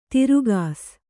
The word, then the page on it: ತಿರುಗಾಸ್: tirugās